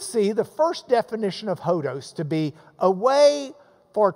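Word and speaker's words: hodos (ὁδός): Your Greek word for the day is hodos.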